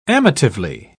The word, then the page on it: amatively: British English